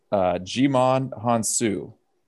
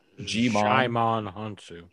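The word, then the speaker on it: Djimon Hounsou: It is Jimon Hounsou. Now, that's the tall, extremely good-looking, very dark gentleman... D-J-I-M-O-N. – Jimon Hounsou.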